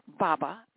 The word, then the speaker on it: Artic Baba: And Arctic Baba, it's spelled A-R-T-I-C. And the last part is Baba, B-A-B-A, Baba. That's the way it's pronounced.